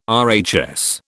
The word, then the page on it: RHS: British English